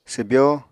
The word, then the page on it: swbyó: apatani